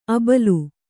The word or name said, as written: ಆಭೀಲ